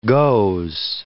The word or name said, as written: goes